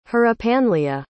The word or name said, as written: hrypanleah